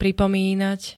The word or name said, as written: pripomínať